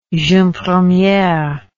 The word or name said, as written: jeune premiere